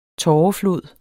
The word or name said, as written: tåreflod